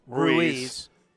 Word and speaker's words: Ruiz: Ruiz. – He stole 86 total bases last year. – Now you might hear it differently. That is the Spanish pronunciation.